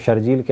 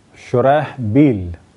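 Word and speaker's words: شرحبیل: لیکن صحیح تلفظ اور اس تلفظ میں عام طور پر اختلاف ہے کہ شرح بیل، عام طور پہ بے کا نقطہ دے دیا جاتا ہے ہے کو اور وہ بن جاتا ہے شرجیل، لیکن شین رے ہے شرح، اور اس کے بعد ہے بے یہ لام شرح بیل،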